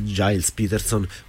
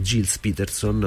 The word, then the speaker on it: Gilles Peterson: organizzato credo da Giles Peterson o Gilles Peterson, anche là la pronuncia inglese francese me l'ha spiegato Julien Verilongu, che praticamente si può dire un po' in tutte e due le maniere.